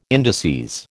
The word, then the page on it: indices: American English